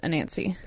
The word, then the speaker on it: Anansi: Like, I really like the spider god, Anasazi? – His name is Mr. Nancy. – When he was just way to remember, his name is pronounced Anansi.